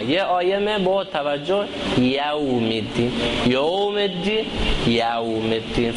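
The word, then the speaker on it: يوم: افسوسه که زیاده لوگان غلط تلفظ کرنگه کهته یه مالک یوم یو نهی یو یا که اوپر فتح زبر هست پیش نہیں ہے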